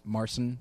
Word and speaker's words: Marsan: I don't know if that's how you pronounce his name. – Marsan. – Marsan. – I know, that's an option. – Say Marsan, exactly. – Eh, Marsan. Eddie Marsan. – Yeah. – Sounds good to me.